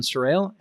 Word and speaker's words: Sarrail: And uh, I'm excited today to be joined by Alan Sarrail.